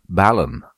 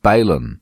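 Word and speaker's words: balun: Ballon is pronounced ballon, not balon, because it's made from two words, balanced and unbalanced.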